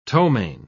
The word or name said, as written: ptomaine